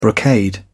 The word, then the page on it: brocade: British English